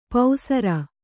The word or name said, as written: ಪೌಸರ